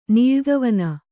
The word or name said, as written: ನೀಳ್ಗವನ